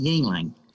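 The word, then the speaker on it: Yuengling: It's pronounced Yingling.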